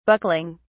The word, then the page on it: buckling: British English